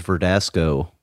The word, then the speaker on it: Verdasco: Well, it's like, so this player, his last name was Verdasco, rhymes with Tabasco, you get the hot sauce, right? – Sure.